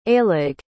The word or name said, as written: aillig